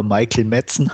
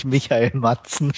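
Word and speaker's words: Michael Madsen: wenn man es Englisch aussprechen würde, würde er Michael Metzen heißen. Aber heißt natürlich Michael Matzen.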